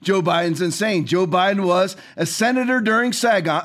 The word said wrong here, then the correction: Saigon: uh, uh why am I pronouncing that wrong? Saigon, Saigon. I couldn't get Sag out of my head.